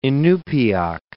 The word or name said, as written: Inupiaq